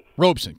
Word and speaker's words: Robeson: What about the idea, and I've read it, and I'm not sure I disagree with it, that Paul Robeson, is that how you pronounce his name? – I think it's Robeson. – Robeson, correct.